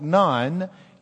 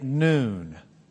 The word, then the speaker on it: nun: That's a noon. You don't say it like a Catholic nun. You say it noon, like N-O-O-N.